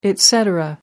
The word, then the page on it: et cetera: American English